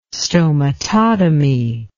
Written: stomatotomy

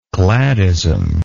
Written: cladism

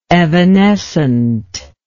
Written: evanescently

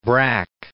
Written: Braque

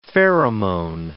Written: pheromone

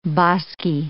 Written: bosky

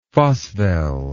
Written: phosvel

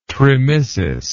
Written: tremissis

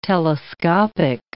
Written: telescopic

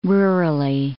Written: rurally

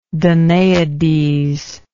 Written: Danaides

